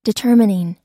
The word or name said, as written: determining